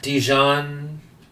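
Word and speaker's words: Diijon: The Mask of Dijon, that's the way you pronounce it, 1946.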